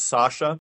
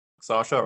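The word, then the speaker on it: Sascha: My first name is Sasha. Sasha, right? That's confusing because the way it's spelled in my case. Mm-hmm. That's because I'm half German. Okay. And in Germany, just imagine my name being spelled without the letter C in it.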